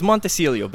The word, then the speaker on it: Montecillo: Uh, you know, it's about the same that most people say. It's Montecillo, but Mon- close enough.